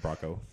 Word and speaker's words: Bracco: Yeah, Brasso. – Yeah. Bracco.